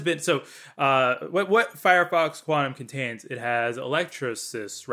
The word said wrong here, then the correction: Electrolysis: right? If I'm pronouncing that correctly. – Electrolysis, yeah. – It's Electrolysis.